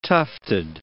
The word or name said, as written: tufted